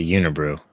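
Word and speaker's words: Unibroue: I think I usually just say Unibrew. – Unibrew? Okay. It could be Unibrew yeah. or Unibrow. But I'll say Unibrew too.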